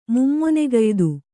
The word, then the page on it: ಮುಮ್ಮೊನೆಗಯ್ದು: Kannada